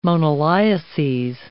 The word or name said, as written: moniliases